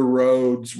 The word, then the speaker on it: Rhodes: One's called Better Roads. Roads spelled like the island, R-H-O-D-E-S.